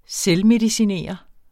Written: selvmedicinere

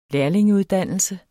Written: lærlingeuddannelse

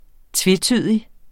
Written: tvetydig

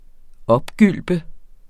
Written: OPGYLP

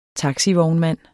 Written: taxivognmand

